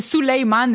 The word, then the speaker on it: Suleiman: Ben, c'est Suleiman, mais mon père l'a tellement écrit n'importe quoi que...